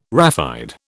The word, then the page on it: raphide: British English